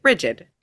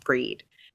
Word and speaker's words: Brigid: and it was beaten into me that you pronounced it Breed. You didn't pronounce it Bridget, it was pronounced Breed.